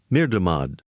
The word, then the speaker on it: Mirdamad: Now here's the name Mirdamad.